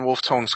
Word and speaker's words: Wolftone: You are now standing in Wolftone Square, Wolfton, as it's pronounced, and I am going to see if I can't come up with a link.